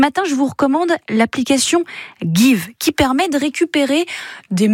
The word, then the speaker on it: Geev: Ça s'appelle Give, comme donner en anglais, sauf que ça s'écrit G-E-E-V.